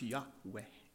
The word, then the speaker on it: Yahweh: And a born-again believer who's a pastor, who's a Jew, said it's pronounced Yahweh.